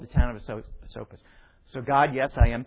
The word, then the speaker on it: Esopus: One was called the Lenapes, and the other was called the Esopus, pronounced exactly the way she said it. You started and then you corrected it. And what you said the second time is the exact way it's pronounced.